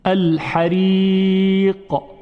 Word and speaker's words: الحريق: الحريق الحريق. ببینید در تلفظ قاف یک حالت قلقله و جنبشی بود، حریق، یعنی یک پرشی داشت این حرف.